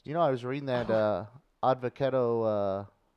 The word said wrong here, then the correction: avocado: Advocato. Avocado. – Sound it out. – Who? Avocado. – Avocado. What did I say? – Advocado. – I said odd. – You're throwing in syllable.